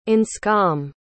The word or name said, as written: anscom